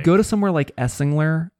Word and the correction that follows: Esslinger: And I don't know if that's actually how you say pronounce their name. Esslinger. – That's how I would say it.